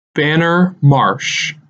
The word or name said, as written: Banner Marsh